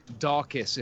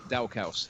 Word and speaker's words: Daukaus: Although I think it's pronounced Daukaus, if I remember, that's how they were pronouncing it. But I prefer to pronounce it like looks, Daukaus.